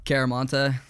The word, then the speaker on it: Chiaramonte: Caramonte.